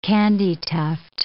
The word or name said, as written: candytuft